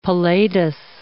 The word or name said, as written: palladous